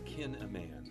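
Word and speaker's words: Kinneman: It's spelled like kill a man, only it's kin a man.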